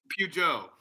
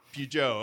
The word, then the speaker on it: Peugeot: Peugeot. Peugeot, okay.